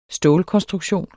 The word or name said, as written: stålkonstruktion